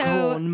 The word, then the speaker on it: Crans: So Cron, Montana. – Crown Montana. Not Crans, Montana.